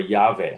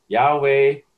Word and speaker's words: Yahweh: It would be Yahovah, right? Or Yahweh, okay? Yeah. – And that's the Hebrew way of pronouncing it. And so Yahweh, Yehovah, that's where the Jehovah, the English or Latinized, right, transliteration of that word, okay?